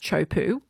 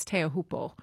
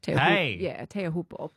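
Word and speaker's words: Teahupo'o: Surfers and commentators casually shorten and mispronounce it as Chopu or Chopes. That's the way they say it. They say Chopes, but it's actually phonetically, I think it's Teahupo. Teahupo. But surfers say Chopes or Chopu.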